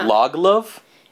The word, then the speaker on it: Lagerlöf: The pronunciation I heard was Selma Lagerlöf. – That's how it looks like when it's spelt. The pronunciation I heard was like, only two syllables. It was just, like, Lagerlöf.